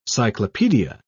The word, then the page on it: cyclopedia: British English